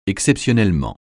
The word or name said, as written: exceptionnellement